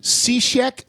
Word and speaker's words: Cishek: Then they bring in this guy named Cishek.